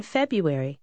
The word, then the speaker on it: February: In February. – Asimismo, va a oír que se pronuncia February. Ambas formas son correctas.